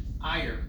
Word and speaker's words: Ire: It's a combination word, but the key is aleph yud resh. Ire. – Okay. – I-R-E. – I-R-E, that I haven't heard that word in a long time. Yeah. Ire is kas.